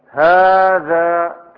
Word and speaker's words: هذا: في مثلها أنتم، وفي هذا، هذا كيف ننطق؟ هذا كيف تكتب؟ هاء والذال مباشرة، ليس بينهما ألف، صحيح؟ صحيح؟ لكن كيف ننطقها؟ هذا في ألف في النطق موجودة، فهي تحذف،